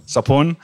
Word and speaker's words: Sophoan: Sophoan.